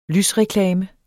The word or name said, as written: lysreklame